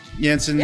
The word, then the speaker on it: Jansen: Nothing against Jansen. – Yeah. – I still have a hard time not wanting to pronounce the J. Like, I know.